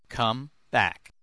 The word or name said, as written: come back